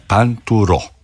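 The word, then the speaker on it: Pinturault: Dobro, za Panturo ću vam reći, njega smo doslovce pitali kako se zove i čovjek nam je rekao da se zove Panturo.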